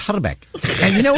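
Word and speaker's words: Hrbek: actual correct pronunciation of his name is Herbeck. You got to roll the I. Herbeck.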